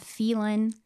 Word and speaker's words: Phelan: So I grew up in the high desert in Southern California in a tiny, tiny little town called Phelan, California, spelled with a P-H. – Will you spell it for us? – P-H-E-L-A-N.